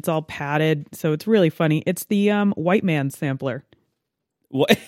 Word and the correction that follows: Whitman's: is, is that how that's pronounced? I believe so. I think it's missing an E. Oh yes, I'm sorry. The Whitman's sampler.